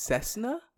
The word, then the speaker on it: Cessna: I feel like a Cessna could be a duck, too. The Cessna 2. The mallard one and the Cessna 2. Come on. – Cessna? – That's what it's called.